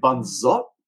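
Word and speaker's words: Bản Giốc: I think the name they pronounce like Ban Zot, but it's so yeah. – Because northern and southern accents are different. So in the south, they'll pronounce it in one way, in the north in another way.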